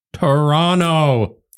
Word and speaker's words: Toronto: Toronto. – Listen, okay? I pronounce the T.